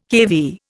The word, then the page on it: kV: Indian